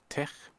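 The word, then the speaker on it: TeX: Oder Tech muss man ja eigentlich korrekt aussprechen. Das griechische Chi.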